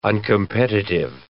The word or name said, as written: uncompetitive